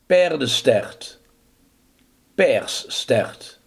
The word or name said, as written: paardenstaart